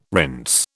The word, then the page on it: rinse: British English